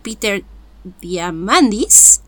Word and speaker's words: Peter Diamandis: Ahí Gisa nos regaló tres habilidades docentes que propone Peter Diamandis.